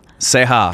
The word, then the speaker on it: Ceja: Ceja.